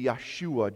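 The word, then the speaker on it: Yeshua (ישוע): The Hebrew way of pronouncing Jesus would be Yeshua, Joshua.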